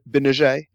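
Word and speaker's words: Gbinije: In the second round out of Syracuse, they picked Michael Binije. Is that close? – Yeah, it's pretty good. Yeah. I'd say Binije. Binije. A little bit softer on that J. Okay. Yeah. Binije.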